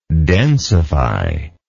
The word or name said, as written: densifier